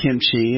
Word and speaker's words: kimchi: So it's a staple, and it's pronounced kimchi.